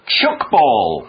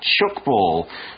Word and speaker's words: tchoukball: Chukball. It's spelled T-C-H-O-U-K-B-A-L-L. Chukball.